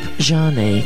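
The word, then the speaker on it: Zhané: The group Jeanne comes next, but it's spelled Z-H-A-N-E.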